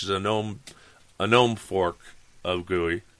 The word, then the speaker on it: GUI: You gotta graphical user interface, GUI, G-U-I, GUI. Trust me, you'll see people spell it GUI too. A lot of times, like goo, G-O-O, then E-Y-E.